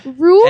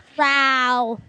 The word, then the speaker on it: Raul: Rule? – And he's not Asian, Raul. So you can't make that joke. – Raul. – Raul Duke. – Ralph? – Raul.